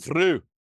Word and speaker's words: Frew: I can't say his name. Frew. F-R-E. – His name is Darren Frew.